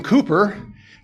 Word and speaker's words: Cowper: A little earlier in 18th century England, a well-known poet and a hymn writer named William Cowper, doesn't look like Cooper, but that's how it's pronounced, he was plagued by recurring depression and insanity.